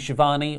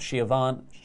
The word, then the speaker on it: Schiavone: And just to wrap up this segment, Tony Schiavone, or Schiavone, however Jericho mispronounces it. – Schiavone